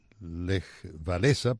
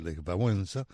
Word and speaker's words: Lech Wałęsa: Lech Wałęsa, que es la forma de pronunciar el nombre de, algunos le dicen Lech Wałęsa, pero es Lech Wałęsa en polaco.